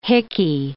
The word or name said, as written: hickey